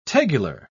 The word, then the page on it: tegular: British English